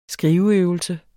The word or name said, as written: skriveøvelse